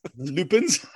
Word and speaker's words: Lupin: Which always cracks me up when we do the Monty Python thing about the Lupins. – About the Lupins. Because that's how it's spelled. It's pronounced Lupin, but it's spelled L-U-P-I-N.